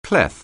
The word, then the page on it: clef: anglais